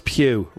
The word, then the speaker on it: Pugh: Florence Pugh rhymes with 22 and Yelena Belova,